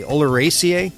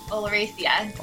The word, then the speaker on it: oleracea: How do I say that correctly? Oleracea? – Oleracea. – Oleracea. Okay. And I've never said it right in my life, but I've seen it a million times, and I've taught about it.